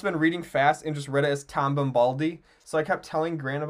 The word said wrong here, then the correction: Bombadil: But I'll never forget that his name's Bombadil, because that's how it's spelled.